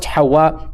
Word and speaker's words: Hawa: Her name is Hawa because it is found in the tradition, and it is pronounced Hawa in Arabic.